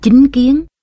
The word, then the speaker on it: chánh kiến: Chánh kiến đôi khi cũng đọc là chính kiến, với ý nghĩa là quan niệm, cách nhận hiểu vấn đề một cách chân chánh.